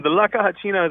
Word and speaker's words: La Caja China: And Jop, you and I over the years have talked to people who use China boxes, only we've been saying it wrong.